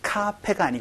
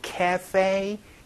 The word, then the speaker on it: cafe: 카페가 아니고 cafe. 요 발음 또 기억을 해 주시면 좋겠네요. 그래서 카페 라떼를 cafe latte 요렇게 얘기하는 발음도 같이 기억을 해 주시면 좋겠습니다.